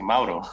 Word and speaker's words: Mauro: You said it right, actually. Mauro. – I've been trying. – So, my legal name is Mauricio. Mauricio Peña. Mauricio in Spanish. But I go by Mauro.